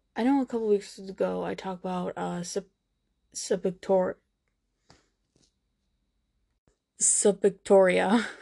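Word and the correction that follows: Suburgatory: Subrogatory. That's how you say it.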